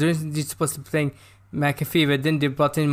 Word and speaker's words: McAfee: What, John McAfee? – McAfee, okay. Yes, yeah. Because I'm saying McPhee.